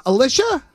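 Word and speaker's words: Alisha: Alicia.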